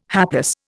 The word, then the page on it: hapless: Indian